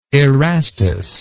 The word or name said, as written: erastus